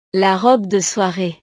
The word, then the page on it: la robe de soiree: frances